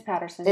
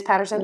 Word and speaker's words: Paterson: I think I'm saying that right. It's one T. – It's Paterson. – It is Paterson.